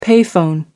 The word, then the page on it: pay phone: British English